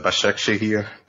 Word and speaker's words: Başakşehir: From what I understand, they pronounce it as Başakşehir, but you know.